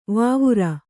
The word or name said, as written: ವಾವುರ